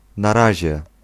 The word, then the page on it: na razie: [n̪aˈraʑɛ]